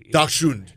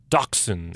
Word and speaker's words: Dachshund: In essence, the dachs, and so people pronounce it in different way. – Dachshund. – Put a k in there too. Dachshund. – Dachshund or dachshund.